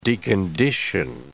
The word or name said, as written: decondition